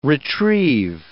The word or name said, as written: retrieve